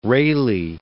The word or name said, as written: Rayleigh